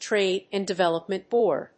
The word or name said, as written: Trade and Development Board